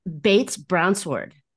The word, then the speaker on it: Bates-Brownsword: Bates Brownsword.